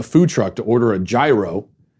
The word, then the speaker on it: gyro: And I noticed, for the first time, I noticed that the guy behind the counter referred to it as a gyro. I think that's how you pronounce it. Gyro, like with a Y. And I thought, is that? And then I looked it up and I went, that's actually what I'm supposed to be saying.